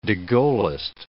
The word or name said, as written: de Gaullist